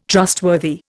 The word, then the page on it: trustworthy: Indian